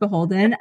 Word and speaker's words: BHLDN: Guys, you probably, I was very unaware that the BHLDN is pronounced Beholden until I met Katie. So in case you guys are new to the bridal industry, that's how you pronounce it.